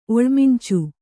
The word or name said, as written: ಒಳ್ಮಿಂಚು